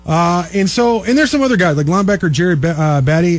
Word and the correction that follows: Beatty: is a, or Beatty, actually, is how you say that.